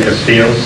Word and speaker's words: Castillo: Gary Higgins, H-I-G-G-I-N-S, classification of parole representative. – Laura Fiamate, F-L-E-M-A-T-E, office assistant. – Victor J. Castillo, C-A-S-T-I-L-L-O, assistant CMPR. – Officer A. Los Santos, D-E-L-O-S-S-A-N-T-O-S, Correctional Officer, C.S.P.